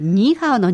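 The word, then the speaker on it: 你好: ニーハオ。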